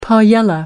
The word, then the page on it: paella: American English